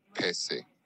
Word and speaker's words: Pese: It's pronounced Pese. So Pese. Like Pele, Pese.